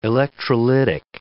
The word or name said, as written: electrolytic